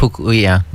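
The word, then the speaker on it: Pukui: I think I'm saying her name right. – Pukuli. Mary Kavena Pukuli, yeah.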